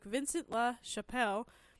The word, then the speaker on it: Vincent La Chapelle: Earliest mention of the souffle is given to French master cook Vincent La Chapelle.